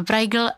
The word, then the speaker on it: Brajgl: A my jsme tam přidali to G a vyslovuje se to Brajgl a co je Brajgl, to víme.